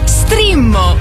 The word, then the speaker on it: Streammo: No, Strimmo! Entra adesso nel mondo di Strimmo. Vai su strimmo.it, si scrive Streammo o scarica l'app.